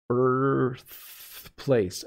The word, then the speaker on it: birthplace: So birth, my tongue, I pull my tongue back, birthplace, and then my tongue comes right up to my teeth. Birthplace, birthplace, worth, fourth, birth.